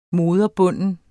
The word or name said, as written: moderbunden